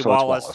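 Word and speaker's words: Wallis: Is it Wallace or Wellis? I thought it was Wallace. – It's W-A-L-L-I-S, so I would be Wallace to me.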